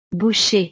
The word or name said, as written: boucher